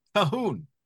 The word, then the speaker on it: Colquhoun: And then I know Dr. Beakey, I've heard him call him Calhoun, but you have said it's really more accurately Calhoun. – Yeah, that's what I'm told. Some either Calhoun or Calhoun, but the Q-U is silent.